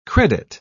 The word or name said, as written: credit